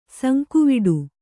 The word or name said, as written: ಸಂಕುವಿಡು